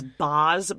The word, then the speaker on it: Baaz: Well, her son's name is Baz, but it's spelled B-A-A-Z. – Baz. – And then her boyfriend's name is Mezier, but it's spelled M-E-Z-E-I.